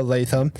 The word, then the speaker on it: Latham: I go, Latham. I go, how do you spell that? Here we go. L-A-T-H-A-M.